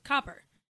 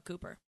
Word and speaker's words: Copper: There's a beer in Charlotte that's called Copper. And it's written C-O-P-P-E-R. Like Copper is spelled. And people always call it Cooper.